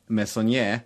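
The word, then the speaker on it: Meissonier: Det finns den här kända franska konstnären Ernest Messonnier som är äh, hyfsat uttal. – Ja, jo, det är bra.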